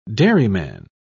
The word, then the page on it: dairyman: British English